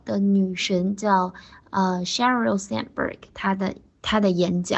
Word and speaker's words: Sheryl Sandberg: 我的女神的名字叫 Sheryl Sandberg。好。 a sh 发好了以后，r l 我们正好复习一下那个 l 的发音，舌头卷上去舔在上牙齿的后面。 Sheryl，Sheryl，Sandberg，Sand 把 n 发好，然后的不要发出来。